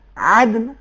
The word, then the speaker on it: عدن: مثال کے طور پر عدن عربی تلفظ میں عدن عین اپنے مخرج سے ادا ہوگا